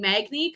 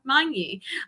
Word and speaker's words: Magni: I'm used to saying Magni because I just adapted that over the years, but it is Sarah Magni.